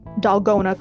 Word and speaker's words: dalgona: So for example, in South Korea, they call it dalgona coffee. It looks like it's spelled like bologna, but it's not. I've checked.